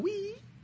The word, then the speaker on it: Wii: Spelled W-I-I, right? – W-I-I. Yeah, yeah. – Pronounced Wii.